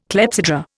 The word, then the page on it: clepsydra: Indian